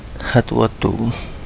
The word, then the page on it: Hadu Uo Duu: Kapingamarangi